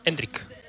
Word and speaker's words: Endrick: Eso descarta la entrada de Endriki. – Puede ser. – Yo quería ver a Endriki. – No me estás cerrando del todo la pronunciación. – Endrick. Hendrick, ok.